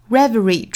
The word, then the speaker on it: reverie: Reverie 重读在第一个音节哒哒哒 Reverie Reverie，